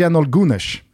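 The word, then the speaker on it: Şenol Güneş: Det är istället, och nu hoppas jag att Ekim Chaglar, vår gode vän som har coachat mig i det här uttalet, blir nöjd. Men det är Jenol Gunesh.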